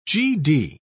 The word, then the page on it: gd: British English